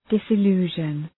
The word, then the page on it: disillusion: ,dısı’lu:ʒən